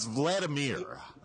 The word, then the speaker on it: Vladimir: He calls them Vladimir. Vladimir. It's Vladimir.